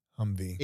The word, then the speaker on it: Humvee: Well, Humvee is short for HMMWV. – Oh. – It's literally people trying to pronounce Humvee.